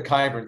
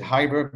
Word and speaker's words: Khyber: The locals pronounced it Khyber because they don't pronounce when there's a KH, they don't pronounce the K. So. The English is way of saying is Khyber, the Pashtun way is Khyber, but anyway, so if you see, you know, I'll probably keep doing that.